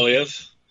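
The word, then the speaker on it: Poilievre: Polivier, is that the name he pronounces? – Well? Polyev, yeah, Polyev, Pierre Polyev.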